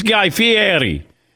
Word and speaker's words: Fieri: And you know, my wife's Italian, so I said, no, it's Guy Fieri.